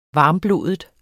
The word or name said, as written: varmblodet